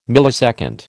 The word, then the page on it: millisecond: American English